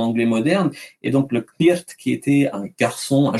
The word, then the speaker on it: Cniht: Et euh, pareil, le, le, le, on avait un titre qui était ça s'écrit C-N-I-H-T, en le prononçant en, anglais de l'époque, ça donnerait Knirt.